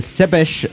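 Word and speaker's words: Țepeș: Vlad III, más conocido como Vlad Cepeș, así se pronunciaría en rumano.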